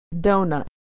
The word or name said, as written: doughnut